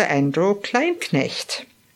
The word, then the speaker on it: Andrew Kleinknecht: Today I'm extremely pleased to be with my friend Dr. Andrew Kleinknecht. I pronounce it the German way, a chiropractor, speaker, and wellness expert.